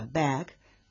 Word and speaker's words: bag: Por ejemplo, la A de la palabra bag, bolsa o bolso, se pronuncia con la llamada A sonriente, y bueno, porque su nombre provoca esa forma en la boca, ¿no?, cuando la pronunciamos. Bag, como decía, empieza con A en su pronunciación y termina casi como una E.